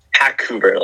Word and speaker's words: Hackouver: is the inspiration behind Hackouver? Is that how you say it? Hackouver? – Hackouver, like a Hackouver. – Vancouver, the city.